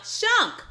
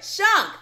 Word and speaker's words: Shunk: Today, I have the great pleasure of having Kristin Skunk with me. Did I say it right? Not Skunk, Shunk. Sorry, Shunk. There we go.